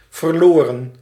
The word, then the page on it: forlorn: /fɔɹˈlɔɹn/; /fɔəlɚɻn/